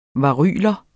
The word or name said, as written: varyler